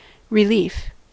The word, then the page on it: relief: American English